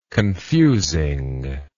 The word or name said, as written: confusingness